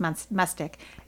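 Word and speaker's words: Mustich: Mustage? Mm-hmm. Mustick. – It's with a CH at the end. But it's pronounced Mustick.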